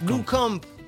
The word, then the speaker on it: Blomkamp: Je sais très mal le prononcer. – Blomkamp. – Tu le dis carrément mieux que moi.